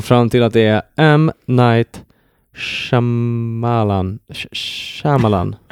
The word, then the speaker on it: Shyamalan: Say M. Night Shyamalan.